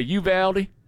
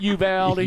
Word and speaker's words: Uvalde: She knew how to say Uvalde. That's how you say it, Uvalde. – Uvalde? – Yes, right.